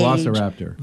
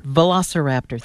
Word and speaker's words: Velociraptor: I hope I'm pronouncing this right, Velociraptor. – Yeah. – Velociraptor cage. – Velociraptor. – Velociraptor, thank you, from Jurassic Park.